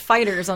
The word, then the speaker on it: Fighters: It's pronounced Fighters, unfortunately. – Okay, just Fighters.